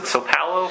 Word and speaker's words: São Paulo: São Paulo? – São Paulo in Brazil.